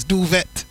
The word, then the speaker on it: duvet: Like duvet. It's got a T on the end. Duvet. It's duvet.